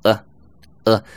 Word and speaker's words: at: 第一种，呃，直接读呃呃，后面的 t 都会省略掉。